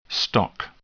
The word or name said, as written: stock